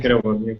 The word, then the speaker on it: Kerouac: Sí, Kerouac.